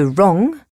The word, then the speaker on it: wrong: Par exemple, certaines lettres ne se prononcent pas. Le W de wrong, le T de listen.